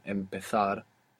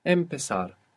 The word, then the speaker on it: empezar: But there's another verb which is more common, which is empezar. Empezar. This is a Z, so that's going to sound th in Spain. Empezar in most of Spain. Empezar.